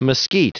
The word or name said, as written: mesquite